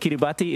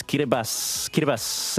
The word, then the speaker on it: Kiribati: We'll remind people every year on the during this week that it's not, like, it's spelled Kiribati, but it's not pronounced that way. Like, look, the spelling is one thing, but the T-I in the language, across the language, is pronounced as an S sound. So if you see a T-I, it's just an S. So not Kiribati, it's Kiribati. And anyways, any reminder out of the way.